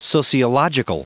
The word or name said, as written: sociological